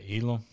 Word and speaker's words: Elam: And then lastly, Detroit at 32, their pick from the Rams, Kyrie Elam, I think I pronounced his name, cornerback from Florida. – You said Elam? – Yep.